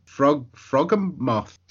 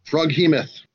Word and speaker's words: Froghemoth: You defeated Speed Bump and Frogmoth. – Froghemoth.